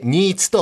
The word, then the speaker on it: 新津: え今回は新潟市秋葉区にあります新津鉄道資料館をご紹介いたします。え新しいという字に、まあ、津軽の津という字で新津と読みますが、この新津という地域、鉄道と大変ゆかりの深い町なんですね。